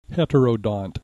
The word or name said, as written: heterodont